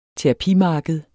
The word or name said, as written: terapimarked